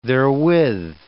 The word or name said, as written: therewith